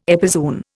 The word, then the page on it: epizoon: Indian